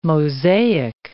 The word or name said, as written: mosaic